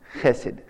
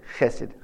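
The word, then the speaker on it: chesed (חסד): In the English, you spell it H-E-S-E-D. But it's pronounced chesed, chesed. It's a hard H, okay?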